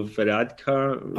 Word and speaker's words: Varadkar: I'd seen that Leo Varadkar. – Oh, my God. – That seemed to be, like, quite a big thing. Did I pronounce that right? – Yeah, you pronounced it correctly.